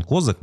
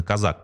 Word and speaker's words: Козак: И Казак, он, это вот правильное, кстати, ударение, я всегда думал, что он Козак, но на самом деле надо было проверить, действительно Казак, поскольку сейчас про него много говорят.